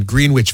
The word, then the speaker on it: Greenwich: Well, remember when I moved here and I said, I'm going to be living in Greenwich Village. – We looked at him like, you're Greenwich Village? – That's how it's spelled. Greenwich.